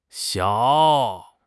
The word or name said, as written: xiao3